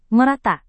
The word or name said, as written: merata